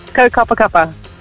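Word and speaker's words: Kaukapakapa: Is that how you say your town? – Kaukaps. – Yeah. How do you say it? – Kaukapa Kappa. – Beautiful.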